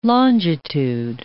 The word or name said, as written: longitude